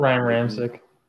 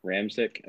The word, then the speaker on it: Ramczyk: Ryan Ramczyk. – Ramczyk, okay.